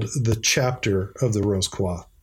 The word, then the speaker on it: Rose Croix: Uh, in in North America, m- many of us say Rose Croix. Uh, just so s- some of the listeners out there may be unfamiliar with Rose Croix, right, right. that pronunciation, but that is the French pronunciation.